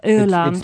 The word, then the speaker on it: Öland: I would go to the tourist information, actually, in this special area of Öland. – It's spelled O with dots over it, L-A-N-D.